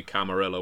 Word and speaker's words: Camarilla: And the thing is, Rich Thomas has subsequently told me, no, it should be Camarilla. We decided on that. – Right. – So I've been pronouncing it wrong as well.